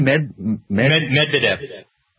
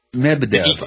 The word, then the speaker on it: Medvedev: Medvedev. – Medvedev.